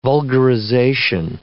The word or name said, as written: vulgarization